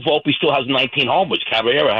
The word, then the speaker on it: Caballero: And I looked it up, Caballero, is that how you say his name? – Caballero, yes.